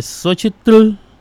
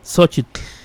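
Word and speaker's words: Xóchitl: Entonces él dice que no se pronuncia Xochitl, que la T y la L es como una especie de salida de aire repentina. Xochitl, así, Xochitl.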